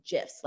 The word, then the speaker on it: GIF: And it was announced, like, this is the correct way to pronounce it's with a soft G sound. So it's GIFs, like peanut butter.